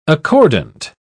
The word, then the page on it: accordant: British English